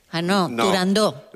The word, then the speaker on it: Turandot: No se debe pronunciar Turandot. – Turandot. – La ópera se titula Turandot.